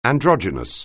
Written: androgynous

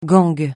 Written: gang

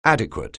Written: adequate

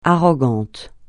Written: arrogant

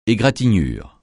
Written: égratignure

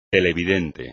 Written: televidente

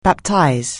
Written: Baptist

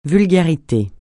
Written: vulgarité

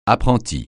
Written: apprenti

